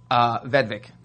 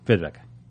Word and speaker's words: Vedvik: Vedvik. – Vedvik.